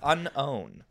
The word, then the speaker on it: Unown: You need a little stop in there. – Take a little breath. Unown.